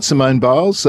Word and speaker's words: Simone Biles: That's right, Simone Biles, after what was a magnificent performance at the US Championships over the weekend.